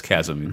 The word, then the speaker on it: chasm: For instance, the leap over the chasm makes no physical... – That's chasm. The chasm. H is silent.